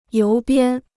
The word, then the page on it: 邮编: yóu biān